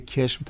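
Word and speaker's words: قشم: خب مثل کشم که کشم تلفظ میشه،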